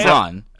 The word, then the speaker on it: Braun: No, Christian Braun. Yeah, Christian Braun. – It should be pronounced Braun. If he wants to pronounce it Brown, we're gonna have to call him Chris Brown.